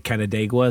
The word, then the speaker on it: Canandaigua: like Canandaigua, is that what it's called?